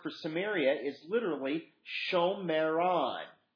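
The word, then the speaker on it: Shomron: But Samaria, if you were literally were going to pronounce it, it's Shomeron.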